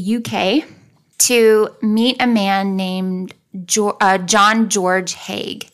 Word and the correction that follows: Haigh: Or sorry, Hay. – Hey. – Hey. But it's spelled H-A-I-G-H, so it makes me want to say Hague. But it's Hay.